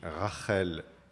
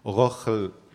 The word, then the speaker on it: Rachel: Et en hébreu, on dit Rachel. Enfin, Laban dit Rachel Biti Aktana, ce qui en prononciation ashkénaze se dit Rochel Biti ou Bissi, je ne sais pas, Haktone.